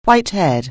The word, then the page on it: white-haired: anglais